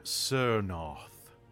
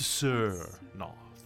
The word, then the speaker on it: Tsernoth: I traded that book to a necromancer named Sirnoth, with a T. To Sirnoth. In the common alphabet, T-S-E-R-N-O-T-H.